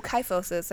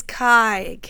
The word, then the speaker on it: kyphosis: Oh, kyphosis. I've been saying it wrong the whole time. – You only said it once before this. It's kyphosis.